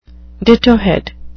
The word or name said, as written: dittohead